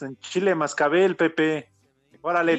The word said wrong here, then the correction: cascabel: Dijo cascabel, tonto, pronuncia con corrección.